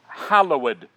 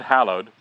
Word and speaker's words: hallowed: Hallowed be thy name. Not hallowed. Hallowed.